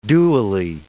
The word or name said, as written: dually